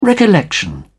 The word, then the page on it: recollection: British English